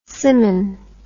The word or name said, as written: simmon